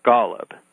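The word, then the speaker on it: Gollub: I've lived in Mexico, where my first name translates easily into Mateo, but where people have a terrible time saying Golub.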